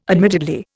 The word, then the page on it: admittedly: Indian